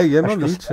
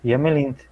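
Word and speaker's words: yamllint: É Yemelint, né? – É, Yemelint, é.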